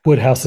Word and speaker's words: Woodhouse: Woodhouse is just how it sounds.